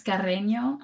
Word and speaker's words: Carreno: It's Carreno.